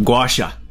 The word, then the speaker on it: gua sha: So that was called gua sha.